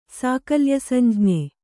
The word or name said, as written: ಸಾಕಲ್ಯಸಂಜ್ಞೆ